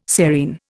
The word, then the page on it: serein: Indian